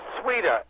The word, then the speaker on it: Sweda: Sweda, S-W-E-D-A.